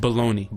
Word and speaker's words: bologna: Baloney. – Baloney is spelled Bologna.